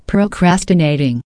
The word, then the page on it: procrastinating: American English